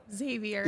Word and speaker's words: Xavier: Xavier. I know. We've been saying Xavier. – It's just Xavier? – Yeah, like Xavier.